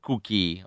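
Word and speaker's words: Kukii: Se vi interessa se vi interessasse andare ad approfondire un pochino il discorso, Cookie si scrive con la K, la U, l'altra K e due I, Cookie, ok?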